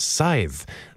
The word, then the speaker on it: scythe: And I had to look up how to properly pronounce that. Scythe.